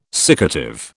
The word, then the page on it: siccative: British English